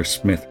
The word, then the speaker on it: Smyther: Kira, of course, was staying with her family at the Smyther Smith residence, and I can't remember which one it is right now because I'm a professional. It's Smith. – Pronounce the second, spelled the first, because it's old timey.